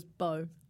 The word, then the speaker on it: Bo: It's like bowl.